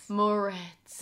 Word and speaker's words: Moretz: Grace, how do you say her last Moretz. – Name? Moretz. – There we go.